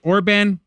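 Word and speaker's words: Orbán: Viktor Orban. Is that how you say it? Orban? – Yeah.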